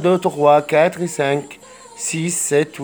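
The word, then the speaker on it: sept: رقم ستة، سيس سيس. سبعة، ست البي لا تنطق، ست ست سي ست.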